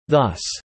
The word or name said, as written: thus